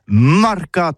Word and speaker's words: marcats: Mais en gascogne toulousain, c'est prononcé les marcats avec un A.